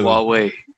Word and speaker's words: Huawei: Like Huawei, how would they pronounce that company? – So Huawei.